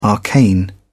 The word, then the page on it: arcane: British English